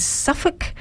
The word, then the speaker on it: Southwark: So the whole time I'm thinking, is he saying Suffolk? What is he saying? It looks like the word south with W-A-R-K, but yes. Say it again. It's pronounced... – Suffolk. – Suffolk.